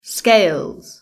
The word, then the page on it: scales: British English